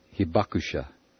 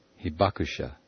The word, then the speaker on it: Hibakusha: Hibakusha. Hibakusha.